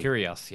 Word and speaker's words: Kyrgios: Well, you've got guys like Nick Kyrgios, whose name I can never pronounce correctly. – Kyrgios, yeah.